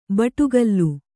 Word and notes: ಬಟುಗಲ್ಲು: baṭugallu

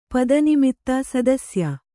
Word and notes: ಸದಸ್ಯೆ: sadasye

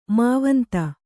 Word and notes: ಮಾವಂತ: Kannada

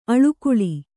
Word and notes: ಆಳ್ಗೊಳ್: āḷgoḷ

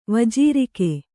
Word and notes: ವಜೀರಿಕೆ: vajīrike

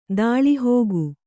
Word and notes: ದಾಳಿಹೋಗು: dāḷihōgu

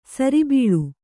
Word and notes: ಸರಿಬೀಳು: kannada

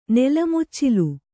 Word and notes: ನೆಲಮುಚ್ಚಿಲು: nela muccilu

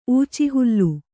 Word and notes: ಊಚಿಹುಲ್ಲು: kannada

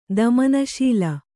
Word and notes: ದಮನಶೀಲ: damanaśila

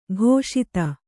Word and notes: ಘೋಷಿತ: ghōṣita

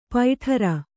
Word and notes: ಪೈಠರ: paiṭhara; Kannada

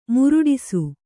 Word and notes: ಮುರುಟಿಸು: muruṭisu

